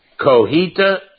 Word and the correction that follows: Cotija: cheese that crumbles, that Mexican cheese. You can get it at the grocery store. Cotija. I get you could probably pronounce it better than I. But Cotija. – Cotija.